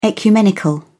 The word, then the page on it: ecumenical: British English